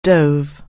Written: dive

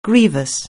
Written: grievous

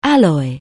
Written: alloy

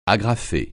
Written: agrafer

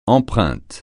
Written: empreinte

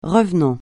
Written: revenant

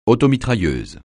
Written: automitrailleuse

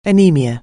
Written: anaemia

